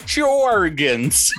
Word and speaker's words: Tchorugons: How do you pronounce it? T C H O R U G O N S. Charugans. – Charugans.